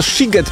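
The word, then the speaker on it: Sziget: Czy możemy ustalić, czy ten siget, mimo że jest przez SZ, to jednak jest sigetem czy sigetem? – Oczywiście, ponieważ w języku węgierskim szy czyta się jak sy, a aha, sy czyta się jak szy.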